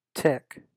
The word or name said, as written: Tick